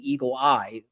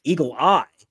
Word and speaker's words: Eagle AI: By the way, if you recognize that name, Eagle Eye, which is how she pronounces it, Eagle Eye, that was back, if you remember, in the 1964 when Barry Goldwater ran.